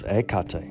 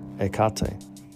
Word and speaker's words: Hecate: Oh, and we have to note she has many names and epithets, but you know, the way to pronounce her name is Ekate. Ekate. So drop the H and just say Ekate.